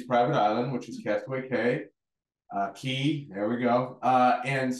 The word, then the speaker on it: Cay: Castaway Cay, not K. K, I'll probably say K. They spell it C-A-Y and it always throws me off, but it's Cay.